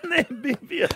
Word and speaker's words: Namibia: At the minute is Nabidia. – No! – How do you say? – You're going to have a third go. – Fart out of your mouth. – Namibia.